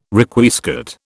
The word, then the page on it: Requiescat: British English